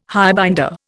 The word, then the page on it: highbinder: Indian